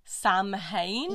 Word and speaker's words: Samhain: Then Samhain, which is spelt Samhain,